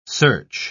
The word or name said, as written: search